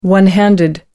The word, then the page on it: one-handed: American English